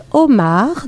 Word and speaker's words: OMART: Les organisateurs sont l'agence de conseil et création Métronomie et l'incubateur d'artistes Omar, dont sont issus les 30 artistes participants. Omar s'écrit O-M-A-R-T, mot valise composé de home, la maison, plus art.